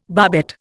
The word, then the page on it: barbette: Indian